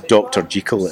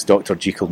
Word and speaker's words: Jekyll: And it's a note to the pronounce is Dr. Jekyll. It was Hollywood that got that bit wrong. It's Dr. Jekyll, not Dr. Jekyll.